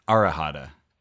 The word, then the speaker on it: Arahata: I think it's ahata. – Aharada? There's an extra syllable in there somewhere. Arahata. That's it. Arahata.